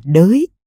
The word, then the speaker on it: đái: như đái thì đọc là đới khỉ đọc là khởi khỉ không